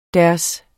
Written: deres

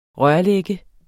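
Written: rørlagte

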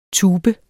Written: tubes

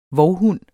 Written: VOVHUNDENES

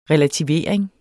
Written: relativering

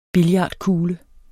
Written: billardkugle

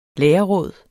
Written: lærerråd

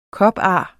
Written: kopar

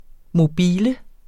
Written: mobile

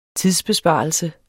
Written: tidsbesparelse

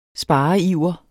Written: spareiver

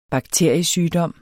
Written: bakteriesygdom

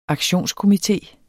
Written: aktionskomité

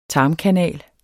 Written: tarmkanal

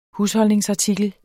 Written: HUSHOLDNINGSARTIKLER

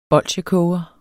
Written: bolsjekoger

